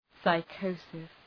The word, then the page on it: psychosis: {saı'kəʋsıs}